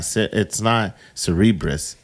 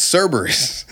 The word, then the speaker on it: Cerberus: It's not Cerebrus. It's Cerebus, the three-headed. – So, actually, I said it fucked up because it's Cerberus.